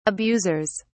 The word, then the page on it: abusers: British English